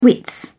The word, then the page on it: wit: British English